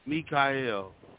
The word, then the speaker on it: Mikael: Well, just want to let the all audience right, well, know the proper pronunciation of my name is like me, like it's M-Y, but it's pronounced me. Mikael.